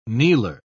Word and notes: kneeler: British English

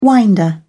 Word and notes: winder: British English

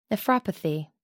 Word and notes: nephropathy: American English

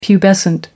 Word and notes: pubescent: American English

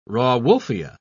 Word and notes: rauwolfia: British English

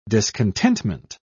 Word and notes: discontentment: British English

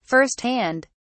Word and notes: first hand: British English

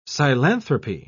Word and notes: psilanthropy: British English

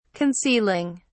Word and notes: concealing: British English

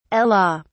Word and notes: ela: British English

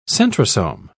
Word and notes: centrosome: British English

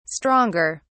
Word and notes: stronger: British English

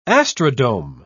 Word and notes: astrodome: British English

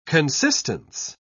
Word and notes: consistence: British English

